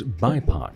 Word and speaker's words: BIPOC: And another program that you have that's just kind of in the footholds at this point is BIPOC.